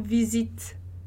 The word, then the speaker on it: visite: Então, je visite, e esse visite, como não tem o E, não tem acento, então, a pronúncia, ela para ali num T, é um T meio assoprado, então é um visite. Tá? Então, o E não tem acento, ele não é pronunciado. E aí, o T vai ser meio soprado ali.